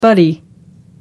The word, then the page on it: butty: American English